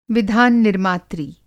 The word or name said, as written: विधान-निर्मात्री